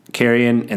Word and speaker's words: Kieron: It's spelled K-I-E-R-O-N, Kieran, and then Gillian is G-I-L-L-E-N.